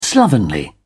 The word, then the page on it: slovenly: British English